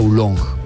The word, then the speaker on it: oolong: Oolong se escribe, pero creo que se pronuncia oolong.